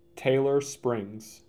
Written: Taylor Springs

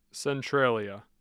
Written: centralia

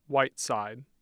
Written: Whiteside

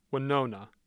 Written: Wenona

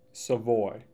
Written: Savoy

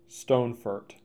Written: Stonefort

